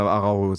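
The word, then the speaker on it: Araújo: Ja, dann müssen wir Araujo sagen, ja. Weil er hat nämlich einen Betonungsstrich auf dem U, deswegen bin ich mir so unsicher, wie das heißt. – Deswegen also, eher so wahrscheinlich Araujo.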